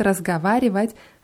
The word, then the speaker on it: разговаривать: Beim, beim Wort разговаривать haben wir ja O in der Mitte. Разговаривать wird es geschrieben. Aber wenn wir das aussprechen, dann ist es besser, da eher bewussten A auszusprechen, weil sonst rutscht das ins I und dann klingt es ein bisschen komisch.